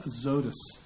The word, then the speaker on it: Azotus: And Philip shows up in a town called Azotus. Is what we came up on Tuesday as the best pronunciation of that name.